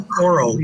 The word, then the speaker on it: Kokoro: Yeah, I really like how do you say that? Kokoro, the last one? – Kokoro, yeah. Kokoro.